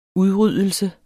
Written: udryddelse

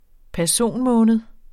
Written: personmåned

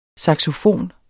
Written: saxofon

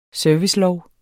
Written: servicelov

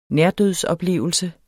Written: nærdødsoplevelse

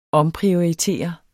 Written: omprioritere

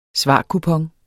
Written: svarkupon

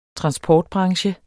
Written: transportbranche

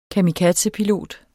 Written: kamikazepilot

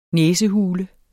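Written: næsehule

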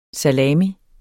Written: salami

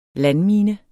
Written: landmine

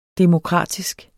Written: demokratisk

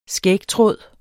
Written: skægtråd

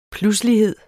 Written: pludselighed

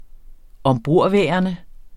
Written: ombordværende

